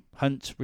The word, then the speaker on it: Hunt: And then Jeremy Hunt, Hunt rhymes with cunt.